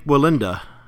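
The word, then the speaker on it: Wallenda: Please pronounce this name. I'm scared I'll mess it up. – it's Nick Willenda. – Nick Willenda. Okay. I was worried it was going to sound differently than it's spelled.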